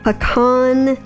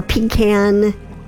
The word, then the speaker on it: pecan: Right now, we're giving back to our 20th anniversary reserve blend, Southern Pecan or Pecan, depending on where you are in the world, how you say it.